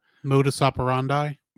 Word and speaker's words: modus operandi: Modus operandi.